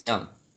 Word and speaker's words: DeJong: Am I saying it right? – Let's go with DeJong. I think it's DeJong.